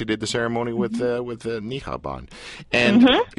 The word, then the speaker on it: niqab: And by the way, how many different ways is this word pronounced? Niqab? I mean, what are we saying here? – Niqab. – Okay. – It's pronounced niqab. – Okay. But isn't that just the anglicized way of pronouncing it? Isn't it niqab? Don't you have to go, I don't know if the Q has a h sound.